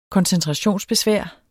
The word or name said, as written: koncentrationsbesvær